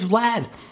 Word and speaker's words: Vlad: His nickname is Vlad. Rhymes with dad and glad.